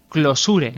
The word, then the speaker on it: Clojure: Dice, tiene que pronunciarse como closure, ¿no?